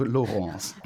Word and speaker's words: Laurence: Laurence heißt also Laurence.